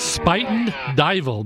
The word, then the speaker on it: Spuyten Duyvil: That is Spuyten Duyvil, believe it or not. I looked it up. That's how you pronounce it. It's spelled S-P-U-Y-T-E-N is Spuyten, and Duyvil is D-U-Y-V-I-L.